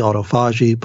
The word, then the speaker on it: autophagy: Some people call it autophagy, autophagy, but apparently the, pronoun- the proper pronunciation is autophagy.